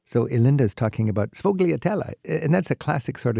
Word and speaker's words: sfogliatella: I know I was walking from Michele's towards the train station, and sfogliatella, something I'm not pronouncing oh, yeah. – it properly. – Oh, yeah, sfogliatella.